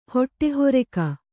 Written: ಹೊಟ್ಟೆಹೊರಕ

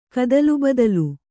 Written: ಕದಲುಬದಲು

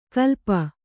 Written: ಕಲ್ಪ

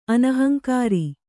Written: ಅನಹಂಕಾರಿ

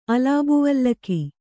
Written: ಅಲಾಬುವಲ್ಲಕಿ

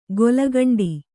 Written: ಗೊಲಗಂಡಿ

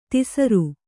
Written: ಧಸರಿ

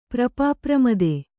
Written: ಪ್ರಪಾಪ್ರಮದೆ

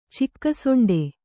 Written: ಚಿಕ್ಕಸೊಂಡೆ

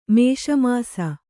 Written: ಮಸಮಸ

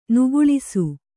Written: ನೆಗಳಿಸು

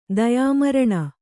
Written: ದಯಾಮರಣ

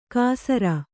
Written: ಕಾಸರ

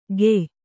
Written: ಖೇಯ

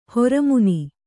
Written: ಹೊರಮುನಿ

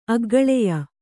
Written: ಅಗ್ಗಳೆಯ

